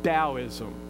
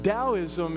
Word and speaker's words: Taoism: I looked at one other religion called Taoism, and it's pronounced with a D, Taoism, but it's pronounced with a T, T-A-O-ism.